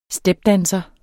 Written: stepdanser

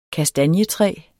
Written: kastanjetræ

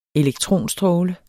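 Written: ELEKTRONSTRÅLE

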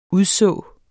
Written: udså

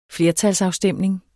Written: flertalsafstemning

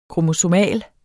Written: kromosomal

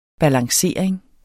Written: balancering

